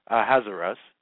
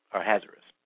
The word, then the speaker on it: Ahasuerus: He sent copies of it, Mordecai, to all the Jews, to the 127 provinces of the kingdom of Ahasuerus. That's the proper way of pronouncing it. I know you can pronounce it in different ways, but that's one of the ways to pronounce it. Ahasuerus.